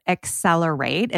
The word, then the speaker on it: XCELR8: Dr. Carol Treasure is the founder and CEO of Accelerate. It's spelled X-C-E-L-R-8.